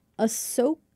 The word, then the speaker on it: Ahsoka: So, Ahsoka? – Yes.